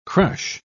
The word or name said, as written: crush